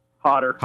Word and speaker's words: Hodder: You going, I mean, seriously, how can people get so excited to meet Kane Hodder at, how do you pronounce it, Hodder? – Hodder. – Hodder, yeah. There we go, that too, Hodder, cold.